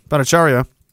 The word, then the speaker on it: Bhattacharya: Bhattacharya. Is that how you say it? Yeah. Bhattacharya.